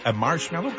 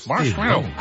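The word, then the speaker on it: Marshmello: La preuve que vous êtes au meilleur de la musique, c'est Marshmallow, comme ça qu'on le prononce. – Ouais, tu l'as bien. – Marshmallow.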